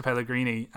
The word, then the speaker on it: Pellegrini: And I think relying on set plays and your kind of big players to kind of chip one in the way that Pellegrino, is that how you say his name? – Pellegrini.